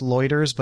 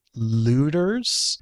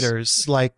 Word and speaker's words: Lueders: It's pronounced Loiters, but it looks like Looters. – I think I was just reading it as Loiters, actually. I didn't realize it was U-E. I thought it was Yeah, no, I believe it's nice. Loiters, like Reuters. Mm-hmm. Not routers, it's Reuters, right?